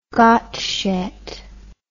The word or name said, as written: Gottsched